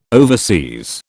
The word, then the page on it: overseas: British English